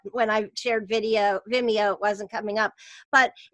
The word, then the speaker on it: Vimeo: Yeah, Vimeo.